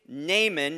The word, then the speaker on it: Naaman: Our whole service today is going to be looking at this case study of a guy named Naaman. Would you say his name with me? Naaman.